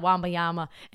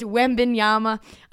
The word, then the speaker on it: Wembanyama: It's not Wembanyama anymore. It's Wembanyama.